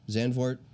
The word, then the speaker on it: Zandvoort: Zandvoort right away after the race.